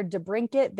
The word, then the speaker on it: DeBrincat: Alex Debrincat or Debrinkit, there was a lot of contingency in how you pronounce his name last night on the, you see Tyler saying it's the second one.